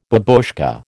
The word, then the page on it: babushka: American English